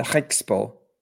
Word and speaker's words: Griekspoor: Ghosty is saying, make say Griekspoor again, and then giggles. – It's a Griekspoor. – Oh. – Griekspoor. Okay. Griekspoor. Yeah. – Griekspoor. Okay. Yeah. I'll that's what my that. Dutch friend tells me.